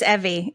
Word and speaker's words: Evvie: It's Evie. It's Evie like like Aww. Chevy.